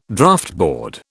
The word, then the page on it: Draughtboard: British English